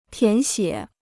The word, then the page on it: 填写: tián xiě